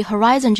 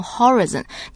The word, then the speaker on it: horizon: 那首先我们注意 "horizon" 这个词的发音哈，大家千万不要念成 horizon, 念的是 horizon。 – Horizon, right? – Horizon.